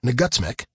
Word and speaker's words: NGUTSCMC: which we lovingly refer to as the NGUTSCMC, pronounced Nagutsmic, for all you linguists out there, I have the happy duty of announcing that we have decided to call a general student strike next week.